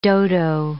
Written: dodo

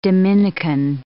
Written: Dominican

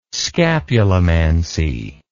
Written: scapulimancy

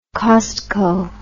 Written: costco

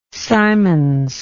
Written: Symons

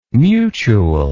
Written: mutule

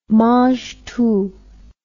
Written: mangetout

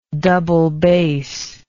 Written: double bassist

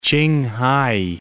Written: Qinghai

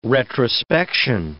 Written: retrospection

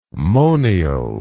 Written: monial

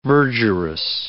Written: verdurous

